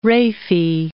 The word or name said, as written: raphe